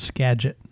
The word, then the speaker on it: Skagit: And I will let you know that it's pronounced Skagit.